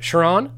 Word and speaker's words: Chiron: Chiron.